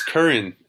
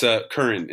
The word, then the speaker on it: Curran: It's Curran, actually. But if you were... – Curran. – I served Russian speaking, so Curran, they would call me Krishna Curran. But yeah, okay. It's Curran in English. It's Irish.